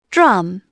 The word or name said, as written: drum